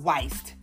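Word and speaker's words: Wiest: The Mountain Is You by Brianna Weist. Her last name is spelled W-I-E-S-T. I thought it was West, but I think it's pronounced Weist if you want to check it out.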